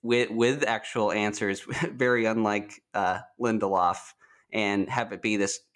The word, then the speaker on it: Lindelof: Damon Lindelof. – Lindelof. All right. All right. Good. – It's like Spindelof.